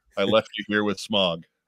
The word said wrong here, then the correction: Smaug: I think it's actually pronounced smaug.